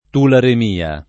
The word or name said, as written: tularemia